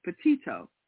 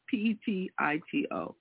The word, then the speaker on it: Petito: Pepit is how I would Pepit? Pronounce it, but I'm not. – I'm trying to get the right. – Petito. There it is. Jennifer Petito.